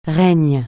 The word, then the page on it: règne: francais